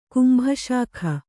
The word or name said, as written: ಕುಂಭಶಾಖ